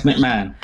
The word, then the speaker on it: McMahon: Vince McMahon.